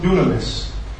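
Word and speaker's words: dunamis (δύναμις): That word power, friends, in the Greek is pronounced dunamis.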